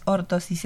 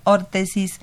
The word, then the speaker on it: órtesis: Y yo nada más quiero hacer una corrección porque pronuncia mal la carrera, no es ortosis, es órtesis y prótesis.